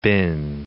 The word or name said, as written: been